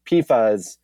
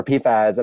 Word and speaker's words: PFAS: And there was a lot of back and forth there for a while, but now it is like established it's PFAS, not PFAs, even though that is the way that we pronounce acronym. That's the way we talk about acronyms.